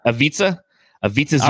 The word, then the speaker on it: Ivica Zubac: Ibaka Zubac. – Ivica Zubac.